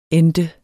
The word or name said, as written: ente